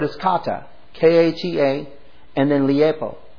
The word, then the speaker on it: leipo: This Greek word to leave is liepo. I'm going to say it that way, and it's spelled L-E-I-P-O, which means to leave. L-E-I-P-O.